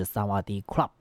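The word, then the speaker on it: 萨 瓦 迪 club (สวัสดีครับ): 但其实呢，这个泰语哈、啊、似乎有这个阴性阳性的这个区别，女生啊讲你好是萨瓦迪卡啊，但是男生呢啊这个发音要是萨瓦迪 club。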